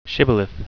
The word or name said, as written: shibboleth